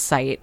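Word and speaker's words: Sÿte: And Sight, like, S-Y-T-E with the two dots. It's pronounced Sight.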